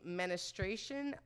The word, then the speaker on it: menstruation: So, members may not broadcast, upload, or any content referencing or depicting menstruation, vomit, feces, and urine. I love that female menstruation, which, by the way, I said it wrong. Menstruation.